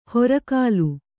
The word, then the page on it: ಹೊರಕಾಲು: hora kālu